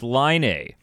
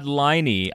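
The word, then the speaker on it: Laine: Speaking of misenunciating word, no, of mispronouncing words, per the NHL's pronunciation guide, it is Line A. – Sean is kind of, he's not Liney.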